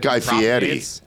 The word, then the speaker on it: Guy Fieri: And I love the Flavor Town that Guy Fieri, technically. Let's be polite. That's how he said that it's pronounced on Hot Ones.